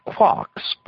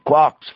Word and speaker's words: quark: The word quark is supposed to be pronounced like quart, but with a K at the end instead of a T. It is usually pronounced to rhyme with lark.